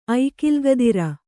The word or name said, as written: ಐಕಿಲ್ಗದಿರ